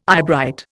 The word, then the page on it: eyebright: Indian